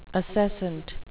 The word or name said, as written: acescent